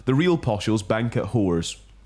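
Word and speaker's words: Hoare's: Whores, but it's spelled... – Whores? Yeah. Interesting. Okay. – It is spelled HORE'S.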